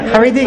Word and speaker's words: Haredi: Yeah, Haredi. – Oh, okay. – Haredi.